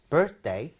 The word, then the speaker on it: birthday: It's Mr. Jackson's birthday tomorrow. You got it. birth. 그 다음에 day, birthday. 강세 어디 갈것 같아요? – 여기 아이요. – 그렇죠.